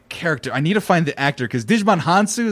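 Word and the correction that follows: Djimon Hounsou: is that how you pronounce it? – Jaimon Hansu.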